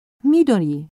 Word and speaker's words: Midori: I-N-O-U-E. – Und wie ist Ihr Vorname? – Midori. – M-I-D-O-L-I? – Nein. M-I-D-O-R-I.